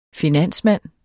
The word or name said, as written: finansmand